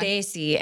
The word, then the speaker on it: Desi: Desi, and you say it kind of with like your tongue out, like a TH almost. – Desi. – D-E-S-I.